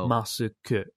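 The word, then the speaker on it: masuku (マスク): Yeah, it's literally just masuku, masuku, masuku, masuku.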